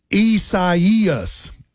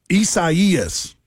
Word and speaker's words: Isaias: Hurricane pronounced Isaias. Isaias.